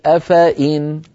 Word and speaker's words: أفإن: انظروا في الآية 144 قوله تعالى أفإن مات من كان منكم معه مصحف الآن فلينظر إلى كلمة أفإن يجد أنها كتبت همزة مفتوحة أ ثم فاء مفتوحة أفا ثم همزة مكسورة أفائي وبعدها ياء وبعدها نون. هذه الياء أيها الإخوة لا تلفظ